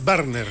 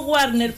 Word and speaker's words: Barner: Corrijo entonces ya la pronunciación, lo había dicho mal, ¿eh? Es Barner, ¿eh? Como en alemán. – La verdad, ¿no?, es que todos nos conocen como Warner, pero claro, es Barner.